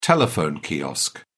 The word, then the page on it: telephone kiosk: British English